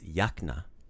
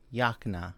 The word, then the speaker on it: jakna: So the word for jacket is jakna. – Jakna. – Yeah, bravo. Jakna. Jakna. – Jakna. – Super. Not something I'm not familiar with, but jakna, proper way to say it.